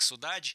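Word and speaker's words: Sodade: Ну, послушаем эту песню «Содада». Она называется, она про тоску по дому в некотором смысле. – Ну, это сложное слово. Да. Это бразильцы произносят как «судадж».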